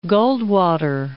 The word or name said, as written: Goldwater